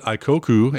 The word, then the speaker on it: Aikoku: and I'm sure I didn't pronounce it as full on those who speak Japanese would pronounce it, but Aikoku, A-I-K-O-K-U, is our word of note.